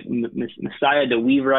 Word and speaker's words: Messiah DeWeaver: So with ODU, I think it's probably down to three guys right now with Stephen Williams, Stone Smart, and correct me if I'm not pronouncing it right, Messiah DeWeaver, I believe.